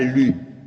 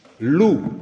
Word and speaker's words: Lu: Et alors, Lou s'écrit Lu, mais ne me dites pas Lu. Ça fait penser au petit beurlu et c'est pas du tout ça. Lou.